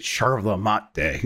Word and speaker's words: yerba mate: Yerba Mate is how it's spelled, but they pronounce it Sherva Mate, and you know I want to be authentic.